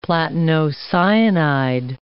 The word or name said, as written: platinocyanide